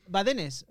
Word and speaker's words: Badenes: How do you pronounce it? – Badenes. – Badenes. – We have just five vowels, A-A-O, so it's quite easy in Spanish.